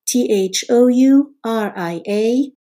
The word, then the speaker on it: Thouria: T H O U R I A.